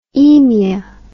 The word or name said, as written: emia